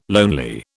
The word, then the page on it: lonely: British English